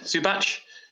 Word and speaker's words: Zubac: Um, Zubac, Zubac, is how you say it? Zubac. – Zubac, yeah.